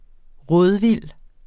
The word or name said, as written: rådvild